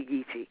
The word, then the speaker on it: Geechee: K-I-S-S-E-E, which pronounced in Gullah would still be Geechee. And so we have Geechee people, we have Gullah people here. They are G-U-L-L-A-H and G-E-E-C-H-E-E.